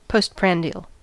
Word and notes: postprandial: American English

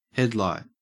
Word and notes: headlight: Australian English

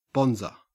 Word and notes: bonzer: Australian English

